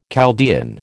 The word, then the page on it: Chaldean: American English